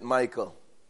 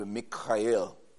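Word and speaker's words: Mikael (מיכאל): We pronounce it Michael, but in Hebrew it would be Mikael, and it means who is like El, El being God.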